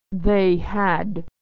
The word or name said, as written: they had